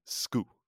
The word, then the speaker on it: Schou: Now, we call him Tim Shu. You might hear somebody else call him Tim Sku, which is apparently the correct Danish pronunciation. But apparently Tim is okay with English speakers calling him Shu.